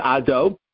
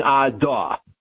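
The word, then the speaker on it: Ado (אֲדֹנָי): He said to me, you are saying the middle syllable with a kamat instead of a cholam. Allow me to explain. He said, it's a common mistake, and the post can talk about it. You're not saying Ado. No, you're not saying that. You're saying Adah.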